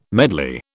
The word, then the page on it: medley: British English